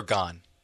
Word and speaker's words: Gane: Cyril Gane. – Okay, see, exactly. – It's the French pronunciation. I don't know if you... – Gane would be that.